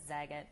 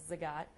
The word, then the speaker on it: Zagat: One of the apps that we did migrate was Zagat, also known as Zagat, but I believe the proper way to say that is Zagat.